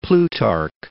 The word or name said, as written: Plutarch